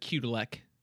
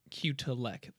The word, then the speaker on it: Kudelik: Yeah, Kudelik. Think of like a, you know, uppercase Q. Leck. – So cute. – Or cutie. Or think of like someone who's cutie.